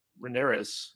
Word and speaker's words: Rhaenyra: But her and Rhaenyra's, is that how you pronounce Rhaenyra.